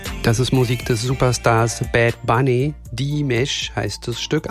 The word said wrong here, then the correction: Diles: Anna, habe ich es richtig ausgesprochen? – Nee, hast du falsch. Nein, falsch. Das heißt Diles.